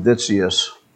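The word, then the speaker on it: Witsius: A writer that I especially appreciate is Hermann Vitsius, spelled with a W, but you know, German.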